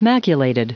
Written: maculated

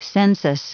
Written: census

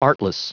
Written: artless

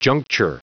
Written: juncture

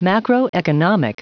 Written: macroeconomic

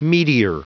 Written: meteor

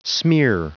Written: smear

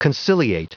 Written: conciliate

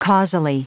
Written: causally

